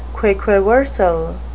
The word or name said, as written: quaquaversal